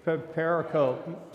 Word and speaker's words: pericope: Pericope.